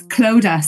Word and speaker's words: Clodagh: Yes, it's Clodagh. So the GH at the end is silent.